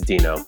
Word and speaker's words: Duenow: As for his last name, it's spelled D-U-E-N-O-W. So I checked with him to make sure I pronounced it right. – How do you pronounce your last name? – Well, that's up for interpretation. It's Dino, I guess.